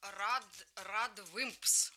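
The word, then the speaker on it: RADWIMPS: Это группа Red Wimps. Red Wimps. Они только... пишут через A.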